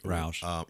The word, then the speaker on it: Rauch: And I just look at Melissa I'm probably say it wrong. Roche, R-A-U-C-H. She played Roush. – Melissa Roush. Mm-hmm.